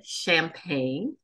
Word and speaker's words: Champaign: She is an associate professor of sociology at the University of Illinois at Urbana Champaign, pronounced like the drink.